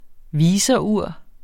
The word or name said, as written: viserur